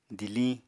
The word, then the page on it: diliñ: apatani